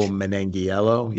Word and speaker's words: Manganiello: Joe Manganiello. Is that how you say his name? – Joe Manganiello. Yeah. – Yeah, that's it. So, you know, you didn't really have those.